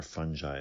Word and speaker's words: fungi: We have barely tapped into the fungal genome, especially the mushroom-forming fungi, or fungi is how he pronounces it.